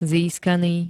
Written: získaný

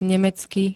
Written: nemecky

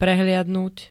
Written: prehliadnuť

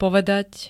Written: povedať

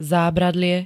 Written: zábradlie

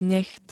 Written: necht

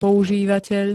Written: používateľ

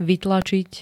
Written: vytlačiť

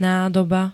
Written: nádoba

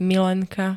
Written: milenka

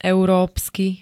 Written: európsky

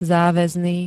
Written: záväzný

